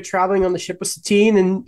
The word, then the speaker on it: Satine: I can't even remember how to say her name. It is Satine.